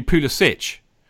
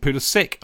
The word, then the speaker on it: Pulisic: If he was from Croatia, it would be Pulisic. Right, because that's how they pronounce it. But the Americans pronounce it Pulisic, I'm led to believe. So that's why we call him Pulisic.